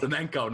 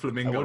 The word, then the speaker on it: Flamengo: Flamengo, not Flamingo.